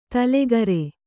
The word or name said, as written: ತೈಲಕಾರ